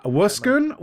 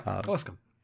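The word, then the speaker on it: Owosekun: Wuskun, which I had been mispronouncing and can now fix. I was like, oh, that's one less syllable than I was using. – Yeah, Wuskun.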